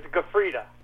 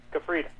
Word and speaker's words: Gufreda: Well, it's my last name is Gufreda. Gufreda.